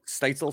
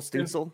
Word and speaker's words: Stützle: is that how you say his name? Stützle. – Stützle. Yeah.